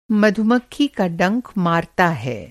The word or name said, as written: मधुमक्खी